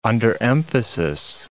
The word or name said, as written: underemphasis